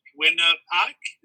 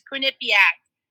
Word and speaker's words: Quinnipiac: I'm probably going to butcher the name, so I apologize, but the Quinnipiac, is that how you say it? – Yeah, that is butchered. Absolutely. It's Quinnipiac.